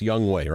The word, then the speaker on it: Younghoe: Now, Young Wei, it's spelled Young Ho, but it's pronounced Young Wei, right? – Yes.